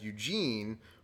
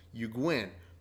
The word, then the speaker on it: Egwene: They're not watching, but there's like a, a ceremony going on with someone that you pronounced in the books as Eugene, but their actual name that they pronounce it in the show as Egwene,